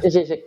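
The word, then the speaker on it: Žižek: There's a certain quote from Slavoj or Slavoj, I'm not sure how to pronounce it, as in Zizek. – Zizek, yeah.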